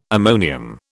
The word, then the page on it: ammonium: British English